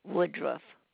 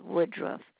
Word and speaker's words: Woodruff: My name is Beatrice Woodruff. And you want me to spell that? – Yes, please. – All right. B-E-A. T-R-I-C-E. Last name is Woodruff. W-O-O-D-R-U-F-F.